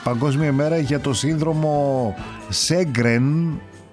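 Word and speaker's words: Sjögren: Πήρε το όνομά του, λέει, από τον Σουηδό οφθαλμίατρο Χένρικ Σέγκρεν. Στα σουηδικά προφέρεται Γέγκρεν.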